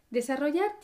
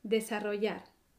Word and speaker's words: desarrollar: Desarrollar tiene cuatro sílabas. De, sa, ro, yar. Cuatro sílabas. Desarrollar. Como es la forma del infinitivo, la sílaba fuerte es yar, la última sílaba. Desarrollar.